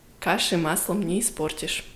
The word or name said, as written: каши маслом не испортишь